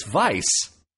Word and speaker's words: Weisz: leaned forward and said, "It's Weisz," and it was the same pronunciation as his name.